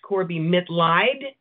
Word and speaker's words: Mitleid: The last name is pronounced Corbie Mitleid, okay.